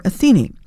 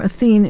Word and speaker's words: Athene: It's called Song for Athene, or Athene is somehow sometimes the way it's pronounced.